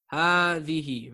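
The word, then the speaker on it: hadhihi (هذه): That is most definitely hadhihi.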